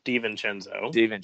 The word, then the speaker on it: DiVincenzo: And kind of like how the Bucks are thin at guard right now with DiVincenzo, probably not even pronouncing that right, but DiVincenzo.